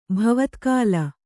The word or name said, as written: ಭವತ್ಕಾಲ